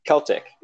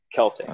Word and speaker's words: Celtic: I even know how to pronounce Celtic the right way. with the hard C, not the soft C. – So wait, how do you say it then? – Celtic.